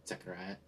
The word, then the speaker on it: Zechariah: And so he's giving us an A to Z, which, clarify, there's no Z sound in Hebrew, it's actually a TS, so Zechariah, something like that. It's a TS sound at the beginning, the CH is probably the guttural sound, and then, so he's doing a first to last in their Bible.